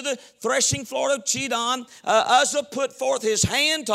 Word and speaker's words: Uzzah: Uzzah.